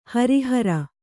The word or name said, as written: ಹಾರಹೂರ